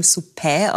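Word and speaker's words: Suppè: Seit 2019 ist der uns allen bekannte Franz von Suppe mit einem, statt einem Accent aigu, mit einem Accent grave versehen, also italienisch geschrieben, Suppe auch ausgesprochen gesprochen, mit einem offenen Ä am Ende.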